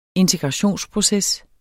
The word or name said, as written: integrationsproces